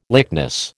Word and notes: lychnis: American English

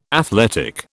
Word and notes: athletic: British English

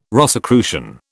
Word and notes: Rosicrucian: British English

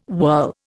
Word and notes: whirl: Indian

